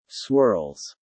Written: swirls

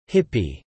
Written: hippie

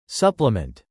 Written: supplement

